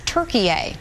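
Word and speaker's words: Türkiye: Officials have notified the United Nations, asking that the country be referred to as Turkiye, the way it's spelled and pronounced in Turkish.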